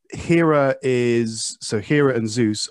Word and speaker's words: Hera: First of all, Hera is how you pronounce it.